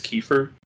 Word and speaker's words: Kiefer: I'd say one of my favorite characters in that game is Prince Kiefer. That's how you pronounce it.